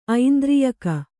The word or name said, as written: ಐಂದ್ರಿಯಕ